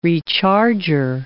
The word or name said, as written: recharger